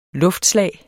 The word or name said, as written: luftslag